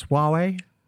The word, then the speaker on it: Huawei: Huawei, is that how you pronounce Huawei? – Huawei, yeah. Huawei. – Huawei, they're in trouble at the moment.